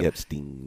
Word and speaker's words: Epstein: Is it Epstein or Epstein? Epstein. Okay, thank you. – Epstein.